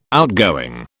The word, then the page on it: outgoing: British English